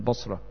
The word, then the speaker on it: Bosra: Busra is an area south of Damascus, spelt B-O-S-R-A in English. That's the way you spell it, Busra.